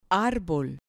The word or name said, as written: arbol